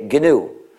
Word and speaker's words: GNU: Donc, il n'est plus nouveau, mais il est toujours GNU. Il n'est plus nous, mais il est GNU. Donc, il faut prononcer le G.